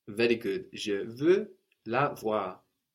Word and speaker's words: voir: To see in French is voir, voir. – Voir. – That's spelled V-O-I-R, voir.